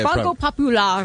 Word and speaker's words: Banco Popular: It's not how you say it, by the way. How do you say it? – Banco Popular. – Thank you. Learn how to pronounce it.